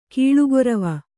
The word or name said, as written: ಕಾಲ್ಗೊರವಿ